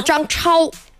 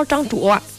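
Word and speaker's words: 张焯: 它就是水生木，对吧，这么一个循环，所以他的儿叫张氏，木字旁一个氏，然后呢木又生火，然后他的孙子呢，他的孙子就是显而易见就是名字里面带着一个火，叫张超，哎，那个张超，张卓，张卓，就是火字旁一个桌子的那个桌嘛。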